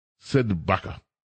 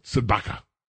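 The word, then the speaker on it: CdeBaca: It's spelled C-D-E, capital C-D-E, capital Sidbaka. It almost sounds like Chewbacca, but Sidbaka.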